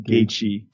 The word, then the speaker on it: Gaethje: Cowboy Cerrone, the highlight, Justin Gage. Can you give me the real pronunciation there? – Justin Gaethje. – Gaethje's got it.